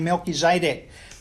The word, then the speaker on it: Melchizedek: His name is Melchizedek, or some people pronounce his name Melchizedek.